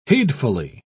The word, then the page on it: heedfully: British English